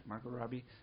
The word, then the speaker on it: Margot Robbie: Is it Margot Robbie? – Mm-hmm.